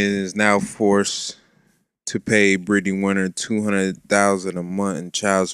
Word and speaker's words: Brittany Renner: Brittany Renner is a Brittany Winner now. – She is Brittany Winner. Brittany Renner. – You said it right.